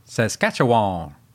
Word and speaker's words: Saskatchewan: Saskatchewan. – Oh, you actually said it right that time.